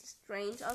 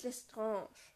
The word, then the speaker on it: Lestrange: Ähm, ich will noch anmerken, eigentlich spricht man, sich ja, spricht man sie ja nicht Bellatrix Lestrange aus, sondern Bellatrix Lestrange. Echt? Französisch, ja, wie genau oh bei Voldemort spricht man ja Voldemort.